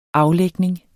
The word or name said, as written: aflægning